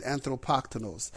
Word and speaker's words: anthropoktonos: That's how you say it, anthropoktonos.